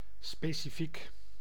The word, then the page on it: specifiek: [spe.si.fiːk]; /spe.si.fi:k/